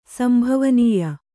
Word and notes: ಸಂಭವನೀಯ: sambhavanīya

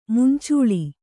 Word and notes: ಮಂಚಲು: mancalu

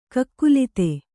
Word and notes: ಕಾಕುಲಾತಿ: kākulāti

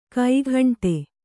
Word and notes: ಕೈಗುಂಟೆ: kaiguṇṭe

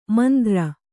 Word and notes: ಮಂದ್ರ: Kannada

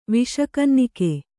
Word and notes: ವಿಷಕನ್ನಿಕೆ: viṣa kannike

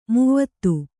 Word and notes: ಮಾವ್ತ: māvta

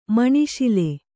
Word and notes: ಮನಸೋಲ್: manasōl; Kannada

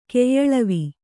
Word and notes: ಕೈಯಳವು: kaiyaḷavu

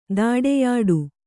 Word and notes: ತಡೆಯೋಟ: taḍeyōṭa

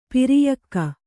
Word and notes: ಪ್ರಯೋಗ: prayōga

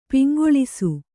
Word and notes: ಪೊಂಗೆಲಸ: poŋgelasa

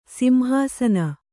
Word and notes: ಸಿಂಹಾಸನ: simhāsana

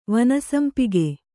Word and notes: ವನಸಂಪಿಗೆ: vana sampige

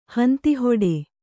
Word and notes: ಹಂತಿಹೊಡೆ: kannada

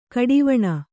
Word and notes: ಘಡಾವಣೆ: ghaḍāvaṇe

